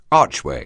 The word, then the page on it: archway: anglais